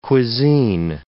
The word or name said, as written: cuisine